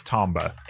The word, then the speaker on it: Tomba: It's, it's I think it's Tomba. – Is it Tomba? I, yeah. And I said Tumba.